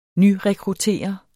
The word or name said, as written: nyrekruttere